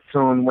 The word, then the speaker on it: Toon: On Facebook, my name is Toon Winning, T-O-O-N.